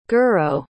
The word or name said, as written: goro